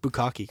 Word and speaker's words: bukkake: At least I pronounced bukkake correctly.